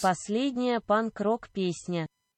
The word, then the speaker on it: Последняя панк-рок песня: And in Russian, it is pronounced a little something like this. – Последняя панк-рок песня.